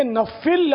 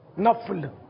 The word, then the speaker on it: نفل: یہ نفل لفظ نہیں ہے، نفل فا پر فا ساکن ہے نا، جزم آئے گا نفل، یہ زبان پہ چڑھا ہوا نفل تو شاید جلدی ٹلے گا نہیں ہے. نفل، فکر، ذکر، یہ سب غلط ہیں،